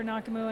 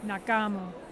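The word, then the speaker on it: Nake'muu: It's N-A-K-E apostrophe M-U-U, and actually pronounced Nakamu.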